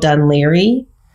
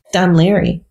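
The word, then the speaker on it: Dún Laoghaire: Yeah, there's an area near us called Dunleary. – Uh-huh. – So I've been looking for it because I was like, well, Dunlegare is down the street. – And that's how they spell it. – Dunleary.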